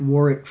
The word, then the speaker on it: Warwick: If you'd like to engage with Warwick and Crucible Leadership on LinkedIn, that's at Warwick Fairfax. That is with the silent W in the middle, W-A-R-W-I-C-K, Fairfax on LinkedIn.